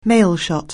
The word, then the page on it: mailshot: anglais